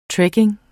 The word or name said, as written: trekking